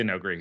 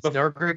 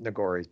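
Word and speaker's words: Noghri: Do you think at any point we'll see the Nogori? – I don't think we'll see the Nogri here. – Okay. It's Nogri, because I've always pronounced it Nogori, but whichever.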